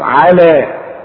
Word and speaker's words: على: طب هي مكتوبة بالياء، تكتب بالياء وتنطق بالالف على.